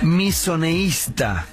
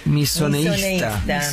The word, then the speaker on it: misoneísta: ¿Qué es misoneísta? Misoneísta. Con acento en la segunda I.